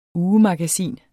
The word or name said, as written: ugemagasin